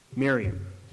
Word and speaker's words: Miriam: But when Jesus speaks her name here in the Greek, it actually gives the Aramaic pronunciation of her name. Miriam.